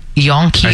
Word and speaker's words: Jonkheer: J-O-N-K-H-E-E-R. – Okay. – So Yonkheer.